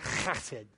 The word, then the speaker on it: chesed: You can spell it with an H if you want, but I just want to make sure you got that, like, you know, that Hebrew, you know, phlegmy sound going right at the beginning of it. Chesed, you know? That's how it's pronounced.